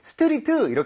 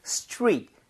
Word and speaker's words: street: 요즘에는 근데 저도 개인적으로 영어 배우시는 분들 중에 스트리트 이렇게 하시는 분들은 못 봤습니다. 스트릿. 이게 TR 그다음에 모음이 나오면 TR이 충돌을 하면서 치읓 비슷하게 발음이 되거든요.